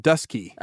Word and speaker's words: Dutschke: But in the South, they pronounced it Dusky.